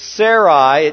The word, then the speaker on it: Sarai: We also know that he was married to Sarai. And I am not mispronouncing her name, I don't think. It is Sarai.